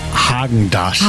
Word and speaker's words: Häagen-Dazs: Okay, spricht man das mit R oder mit A aus? – Man spricht es mit A aus. Ich sage auch, es heißt Häagen-Dazs.